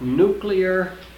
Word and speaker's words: nuclear: So it's pronounced nuclear, not nuclear.